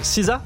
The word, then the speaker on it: SZA: Kill Bill version musique, ça donne ce morceau de SZA qu'on vient d'écouter. – SZA. – Juste là. SZA. Ça se prononce SZA ? – Ouais, on est d'accord.